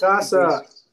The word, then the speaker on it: Tsukasa: Yep, Tsukasa. – And they get into this whole philosophy debate about, like, who they should bring back and who they should just kill because Shishio wants... – Tsukasa!